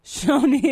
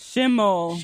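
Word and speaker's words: Shoni Schimmel: Did I say it right? – No. – Simi Shomo? – Shoni. – Shomo Shomo. How do you say it? – Oh, my gosh. Shoni. – Shoni Shimble. – Shimmel. – Shimmel. I like Shoni.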